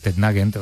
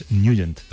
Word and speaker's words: Nugent: Hombre, siempre lo hemos llamado en el barrio Tednagent o Tednagent. – Claro, ¿no? Yo era de Tednagent. – Pero la pronunciación correcta es Nugent. – Te fuiste a Washington y descubriste que era Nugent.